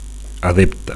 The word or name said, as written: adepte